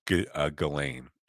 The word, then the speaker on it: Ghislaine: we're talking about Jizzy Maxwell, Ghislaine, I'll say her name right once, Ghislaine, I'll call her Jizzy, she's always Jizzy, my Jizzy.